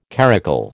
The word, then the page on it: caracul: American English